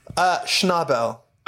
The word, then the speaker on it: Schnabel: How do I pronounce his last name before I butcher it? – Schnabel.